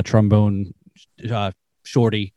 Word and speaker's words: Trombone Shorty: and they were recorded a song with trombone Shorty, and did I say that right? – Yeah.